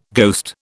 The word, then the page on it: ghost: British English